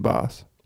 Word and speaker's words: Bass: It's pronounced Boss. – Boss. – Is it Boss? – Yeah, Boss.